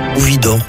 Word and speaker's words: Widor: Alors, le troisième morceau de musique retenu est la toccata de Charles-Marie Widor. Je suppose que je prononce bien. – Widor. Les deux sont, les deux sont acceptables.